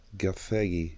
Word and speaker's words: Gathegi: Eddie Gategi? – Spell it. – Gathegi. – G-A-T-H. Gathegi. Gathegi?